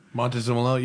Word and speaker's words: Montezemolo: Montezemolo, yeah.